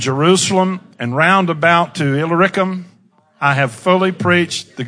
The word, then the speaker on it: Illyricum: Illyricum. Say with me, Illyricum. And that's a way to pronounce that.